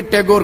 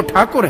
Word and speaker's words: ठाकुर: अब रविंद्रनाथ ठाकुर को टांग पकड़ के टैगोर कर दिया, अब टैगोर नहीं है वो, ठाकुर है, लेकिन वो प्रोनाउंसिएशन उसका ऐसा है, गंगा जी गांजीज हो गई, ठाकुर टैगोर हो गया,